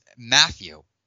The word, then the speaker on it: Mathieu: You say Tyrann. It's Matthew.